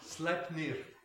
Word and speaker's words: Sleipnir: Vous voulez que je le prononce à la norvoise? – Oui. – Sleipnir. Voilà la bonne voix.